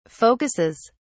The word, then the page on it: focuses: British English